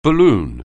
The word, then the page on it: balloon: anglais